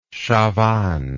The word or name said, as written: chavannes